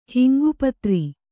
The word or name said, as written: ಹಿಂಗುಪತ್ರ